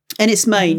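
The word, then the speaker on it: Enys Men: It's pronounced Ennis Main, is it? – Ennis Main, yeah. It's Cornish for Stone Island.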